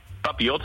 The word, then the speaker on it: papillot: Ja, is wat jij doet, papillon, spreek je er goed uit, papillon? – Papillot, papillot.